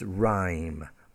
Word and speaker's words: rhyme: The rhyme in the poem, it had a nice rhyme, R-H-Y-M-E, but sounds like R-Y-M-E, but spelt R-H-Y-M-E, rhyme.